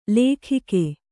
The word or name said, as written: ಲೇಖಿಕೆ